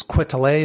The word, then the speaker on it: Quetelet: I think his last name is Quetelet, is how you say it.